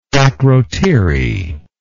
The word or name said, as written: Akrotiri